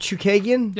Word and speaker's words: Chookagian: Caitlin, is it Chookagian? – Yep.